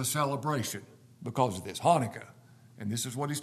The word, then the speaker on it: Hanukkah: Is that the way you say it? Hanukkah? The Jewish Hanukkah? Okay, I said it wrong, sorry. Hanukkah.